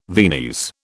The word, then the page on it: Viennese: British English